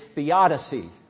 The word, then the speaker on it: theodicy: It's theodicy. Theodicy.